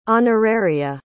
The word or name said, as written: honoraria